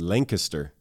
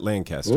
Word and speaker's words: Lancaster: Give me a point for this because you mispronounced the city. It's Lancaster, not Lancaster.